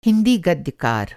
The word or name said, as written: गद्यकार